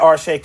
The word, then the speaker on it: Arshay: Yes, at Arshay Cooper on Instagram and on Twitter. That's simple. – It is. Arshay, A-R-S-H-A-Y.